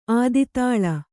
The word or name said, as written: ಆದಿತಾಳ